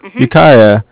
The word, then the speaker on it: Ukiah: Ukiah.